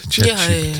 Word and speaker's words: ChatGPT: Zeg ik het goed? ChatGPT. Ja, ja.